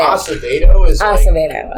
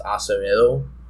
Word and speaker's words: Acevedo: Acevedo. – Like okay. – The gentrified version, but like if you want to sound like you're Colombian, then it's Acevedo.